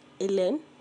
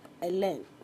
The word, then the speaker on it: Hélène: And in 1989, Hélène, pronounced as Hélène.